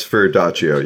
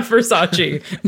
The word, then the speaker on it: Verdaccio: As far as I know, it's Verdaccio. Yeah, you said it. – Okay, right. – Thank you. – Yeah. – Not Versace.